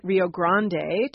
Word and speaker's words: Rio Grande: Rio means river, and you and I are both pronouncing it Rio Grande to try to capture its Spanish origins. You will hear people say the Rio Grande, which is a sort of Englishified version of that.